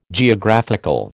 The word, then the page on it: Geographical: American English